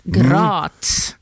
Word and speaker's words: Graz: Han jobbar annars vid universitet, det kan inte jag uttala, universitet Graz. – Graz. – Graz i Österrike faktiskt.